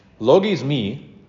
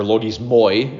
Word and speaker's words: logismoi: Logismi, or logismoi, if you're going to do the Erasmian pronunciation, which just grates on my ears because that's not the way I was taught. So if you want to bug me, say logismoi every time you see me. L-O-G-I-S-M-O-I, logismi.